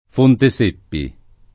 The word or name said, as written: Fonteseppi